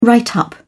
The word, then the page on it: write up: British English